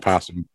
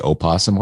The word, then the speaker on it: opossum: It's spelled opossum and pronounced possum. – Oh, silent. – Why would somebody put an O on it? – Because it's silent.